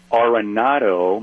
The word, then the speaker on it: Arenado: Arenado, Matt, as you and I both knew.